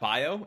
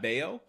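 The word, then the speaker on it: Baio: Yeah, a a certain uh, person wrote in to say uh, that we mispronounced Scott Bio's name as as Scott Bio instead of Scott Bayo.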